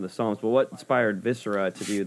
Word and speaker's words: Viscera: You just mentioned how you did the one with the Psalms, but also now with your most recent release, Viscera, is that how you pronounce it? – Yeah, Viscera.